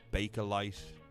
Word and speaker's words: Bakelite: You can spell that C-E-I-N-T-S-D-E. Bacalite, Bakelite, B-A-K-E-L-I-T-E.